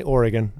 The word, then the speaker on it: Oregon: Tell me how you say it. – Definitely Oregon. – Oregon. – Not Oregon. – Okay, you never know. – No, you do know. It's Oregon.